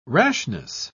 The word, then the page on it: rashness: British English